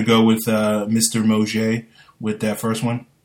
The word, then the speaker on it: Mauger: I thought it was Mauger. – No, it's it's Moje. Well, apparently it's Moje from the official uh, pronunciation guide.